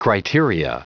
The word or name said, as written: criteria